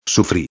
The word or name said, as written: sufri